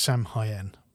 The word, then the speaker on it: Samhain: starting out as the festival of Samhain, which I'm definitely not pronouncing properly, but I'm not Celtic and 2,000 years old, despite what I look like. – I believe it is actually pronounced Samhain.